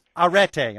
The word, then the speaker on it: arete: You argue that if there was one word we should take away from ancient Greece, it would be arete.